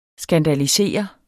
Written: SKANDALISEREDE

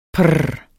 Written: prr

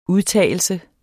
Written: udtagelse

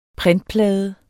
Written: printplade

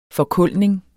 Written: forkulning

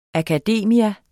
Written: akademia

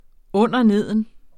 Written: underneden

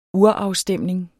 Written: urafstemning

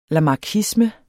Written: lamarckisme

